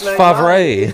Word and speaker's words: Favre: Everything might be silent. – Yeah. Favre. It's pronounced Favre.